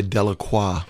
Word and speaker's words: Delacroix: Well, I remember years ago with Casey Delacroix, or as the Americans say, Delacroix.